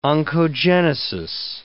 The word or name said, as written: oncogenesis